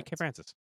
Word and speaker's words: Kay Francis: Is it just me or does Kay Francis, am I saying that right? – Yeah, Kay Francis.